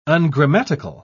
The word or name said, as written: ungrammatical